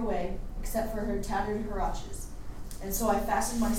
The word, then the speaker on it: huaraches: They were huaraches. – How do we pronounce that? – Huaraches.